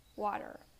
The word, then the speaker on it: water: The first of all is water the T turns into a D.